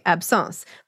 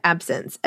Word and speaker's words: absence: Out loud, you pronounce it the French way, absence. But when I see it in writing, I hear it in my head as the English word absence, as in being absent, which makes perfect sense to me because the way this seizure works, the person is there, but they're not there.